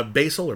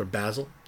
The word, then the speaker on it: basil: Basil or basil, depending on how you prefer to pronounce things, is like marijuana.